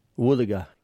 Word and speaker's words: wuthuga: Woothaga is spelt W-U-T-H-U-G-A. Woothaga.